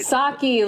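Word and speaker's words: Psaki: Saki? Is it right? – Saki, like Saki Bomb.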